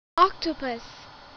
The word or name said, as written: octopus